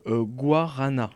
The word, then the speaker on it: guarana: Il y a également le guarana, c'est bien ça? – Exactement. – C'est bien prononcé.